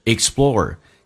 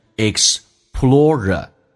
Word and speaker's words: explorer: 那么下面一个听力难点就是 explorer，这个单词在英式发音当中可以读成 explorer 或者 explorer，那么美式发音当中有时候偏向于发成 explorer，那个 r 二就没有 explorer，所以呢能听懂 explorer 跟 explorer 的一个区别是什么。